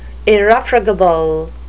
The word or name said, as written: irrefragable